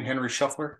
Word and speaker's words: Shuffler: Sad part is I'm I'm looking at some of the you know uh, Shuffler. Is that how you pronounce his name? Henry Shuffler. Mm-hmm.